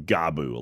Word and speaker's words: Gabu: It's pronounced Gabu. Yes. – Uh, yes, Gabu. – Gabu. – Okay. Some people go Gabu, and I'm like, it's just.